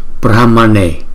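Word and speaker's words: Brahmaṇe: Thì cái cách chúng ta đọc danh hiệu Đức Phật thứ 13 nè, Brahmane, chúng ta chú ý chữ N có dấu nặng thì chúng ta đọc là N, N, đọc cong lưỡi lại đọc N, Brahmane, Brahmane, Brahmane.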